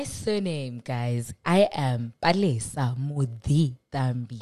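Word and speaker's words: Muditambi: But the correct pronunciation of it is Mudi Thambi.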